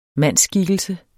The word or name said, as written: mandsskikkelse